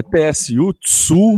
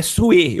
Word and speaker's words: Tsu: É TSU Tsu? – Não, é, é, é Tsue, é Sue. Eu vi, eu vi uma matéria explicando, é Sue, é Su, você pronuncia. Su.